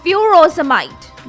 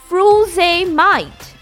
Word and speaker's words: frusemide: So it sounds as furosemide. Okay? F-R-U-S-E-M-I-D-E. While in USA, it is F-U-R-O-S-E-M-I-D-E. So the spelling is changed and the pronunciation changes.